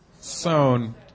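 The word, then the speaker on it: Soane: Speaking of London tourist things, we went to the John Soames Museum. Someone pronounce it for us. – Soan.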